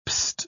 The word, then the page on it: pst: British English